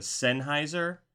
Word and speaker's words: Sennheiser: And correct me if I'm pronouncing this wrong, the Sennheiser. – That is 100% correct.